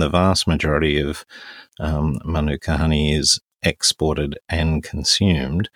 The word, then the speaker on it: manuka: manuka honey, and um, and it uh, did I say it properly? Yes, good manuka honey.